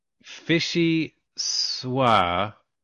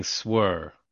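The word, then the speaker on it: vichyssoise: It's what fishy. Fishy swa. And I've been saying swer. Carl's Fishy Swa. But it's spelled fishy, like fishy, and then S-S-S-S-O-I-S-E.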